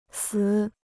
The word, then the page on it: 死: sǐ